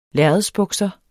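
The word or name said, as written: LÆRREDSBUKSER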